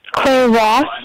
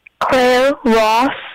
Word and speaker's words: Claire Ross: Claire Ross. – Sorry? – Claire Ross. – Can you spell your name? I want to say it correctly. – C-L-A-I-R-E.